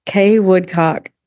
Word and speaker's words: Kay Woodcock: Kay Woodcock. K-A-Y-W-O-O-D-C-O-C-K.